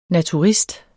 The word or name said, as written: naturist